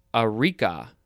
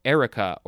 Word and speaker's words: areca: Now we have areca, A-R-E-C-A, could also be pronounced erica or areca.